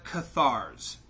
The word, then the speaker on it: Cathars: But for this week's Nook of Darkness, we have the Cathars. Am I pronouncing that right? C-A-T-H-A-R-S? – Sounds right.